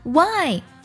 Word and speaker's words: why: 第四组呢，就是 W H 开头，H 不发音，像 Why 为什么，还有 White 白色，其中的 H 都不发音。